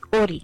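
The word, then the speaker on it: 오리: D-A-K. 닭. 닭. Duck is pronounced as 우리. – 우리. – 우리. – 우리. – Not 우리 like us. Ori. – Ori. – O-R-I. Ori. – Ori. – For duck. Chicken is duck. And duck is ori.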